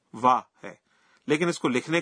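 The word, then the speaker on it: وا (は): میں حیران ہوں کہ جب ہم ہیرا گانا میں لفظ وا لکھتے ہیں تو ہم وا کے بجائے ہا کا حرف استعمال کرتے ہیں. تاہم اس کا تلفظ وا کرتے ہیں.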